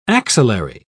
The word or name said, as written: axillary